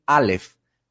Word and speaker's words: Aleph: Pero cuando yo veo el nombre de la letra Aleph, que se dice así, Aleph,